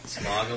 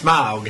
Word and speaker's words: Smaug: I sort of thought they undercut the threat of Smaug a little bit with some of the shit. – You're not saying it right. It's Smaug.